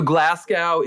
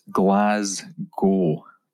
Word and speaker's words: Glasgow: So Glasgow is a better city than Edinburgh if you're a watch person? – That's not how you pronounce it. It's Glasgow.